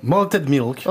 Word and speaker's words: Malted Milk: Oh, comment vous prononcez? – Malted Milk. – Oh c'est ouais, bien, ouais, ça me plaît. – On peut le dire en français, Malted Milk.